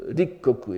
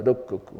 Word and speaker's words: Rikkoku: on prononce Rikkoku et pas Rokkoku, hein.